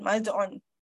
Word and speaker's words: Maz'un: Okay, or you can spell it as M-A-Z apostrophe U-N, Maz'un.